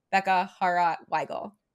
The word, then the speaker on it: Bekah Hawrot Weigel: So this takes all of that away and I can just send them my profile that says Bekah Hawrot Weigel.